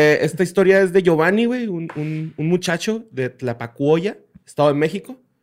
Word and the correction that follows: Tlapacoya: ¿Sí lo dije bien? ¿Tlapacuoya? – No sé. – Tlapacoya. ¿Tlapacoya?